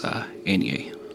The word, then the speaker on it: Eithne: So, N-E-A is actually... – Go phonetically. – E-I-T-H-N-E. It's an older version of Enya, actually. And then Leiden is actually L-I-A-D-A-N...